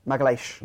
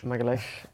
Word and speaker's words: Magalhães: I've just gone with Magalhães. – You can never pronounce it, can you? Magalhães. – Magalhães.